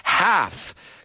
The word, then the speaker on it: half: I hope you guys are relaxed, or at least half. Cuidado, la L ahí no la pronunciamos.